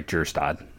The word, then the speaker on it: Kjerstad: And last year they went pretty massively under slot with Kjerstad and, I'm sorry, Kjerstad. You don't pronounce the K.